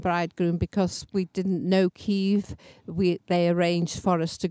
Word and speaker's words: Kyiv: Just to explain, listeners, we know it as Kiev, but their pronunciation is Kyiv, isn't it? – That's right, yes.